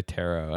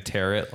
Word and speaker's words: tarot: Well, I don't know if I learned recently, but I heard someone say tarot, and I was like, I was definitely saying tarot, like carrot. – Yeah, the T is silent at the end.